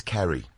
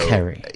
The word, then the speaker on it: Ceri: The Irish way is with a K, K-E-R-R-Y or K-E-R-R-I. – Okay. – But Ceri is a Welsh name, yeah, because we pronounce our C's as though they were K's. So Ceri. – Yeah. So Ceri, C-E-R-I.